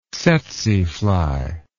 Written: tzetze fly

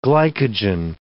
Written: glycogen

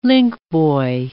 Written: linkboy

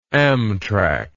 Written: Amtrak

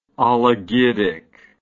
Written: oligidic

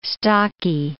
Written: stocky

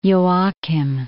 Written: Joachim

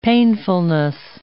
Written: painfulness

